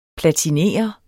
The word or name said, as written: platiner